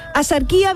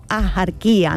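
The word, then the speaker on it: Axarquía: Y aunque hoy la RAE deja claro, la RAE dice que lo culto y correcto es pronunciarlo con J, es decir, asarquía, la tradición local sigue pronunciando y no solo escribiendo asarquía o ajarquía, ¿no? Con una H aspirada, con una H aspirada.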